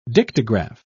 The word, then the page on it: dictograph: British English